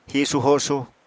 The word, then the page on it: hisu-hosu: Apatani